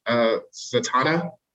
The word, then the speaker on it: Zatanna: They canceled Zatanna. That's how you say it, right? – Yeah.